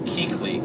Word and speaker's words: Kuechly: There's a couple of people in here saying it's pronounced Kuechly. – Kuechly, whatever. Fine, I was saying it wrong.